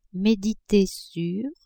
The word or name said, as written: mediter (sur)